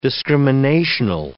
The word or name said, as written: discriminational